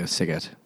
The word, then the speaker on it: Sighet: No, he's in like Sighet. – Is that I don't know how to pronounce oh, that. Oh yeah, Sighet.